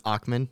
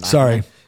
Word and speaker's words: Ackman: So I think that, you know, you brought up Bill Ackman. By the way, I'm going to correct you. It's Ackman, not Ackman.